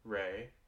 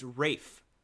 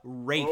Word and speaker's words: Ralph: It's pronounced Ray. – No, it's Rafe. It's pronounced Rafe. – Oh. – Yeah. R-A-L-P-H is pronounced Rafe.